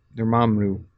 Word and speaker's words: Dormammu: Yeah, but it's like three syllables, though. Dormammu.